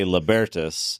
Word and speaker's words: Libertas: Well, so we're very libertarian, which means we don't really care how you pronounce it. You can pronounce it however you want. We say Libertas.